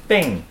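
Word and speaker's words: Bing (病): It's a high pitch down to a lower pitch. – Okay. – Ing. Ing. – You're... What? – That's how you pronounce it. Bing.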